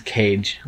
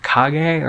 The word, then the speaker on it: Kage: They're like, the most recent Angry Video Game Nerd video is Legend of Kage, I think he pronounced. I thought it was Cage. Legend of Cage. And I was like, oh, Legend of Cage, what's that? And then he was like, it's actually Legend of Kage or something.